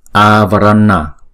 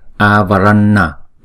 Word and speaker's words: avarana: Vigata, vigata avarana, chữ a là hai nhịp, còn chữ n là cong lưỡi lại búng ra. Avarana, avarana. Chúng ta không đọc là Vigata, đó là Vigata, Vigata.